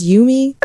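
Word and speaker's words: Yuumi: And did I say that right? It's Yumi? – I think so. I heard someone say yummy the other day, and it freaked me out.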